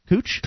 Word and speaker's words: Kuch: If convicted of either of the first two charges, Kuch, K-U-C, give me a pronunciation on this. K-U-C-H. Kuch? – Kuch.